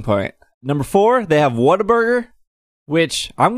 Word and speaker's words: Whataburger: And is it pronounced Whataburger? – What-a-burger? – Yes, not Whataburger.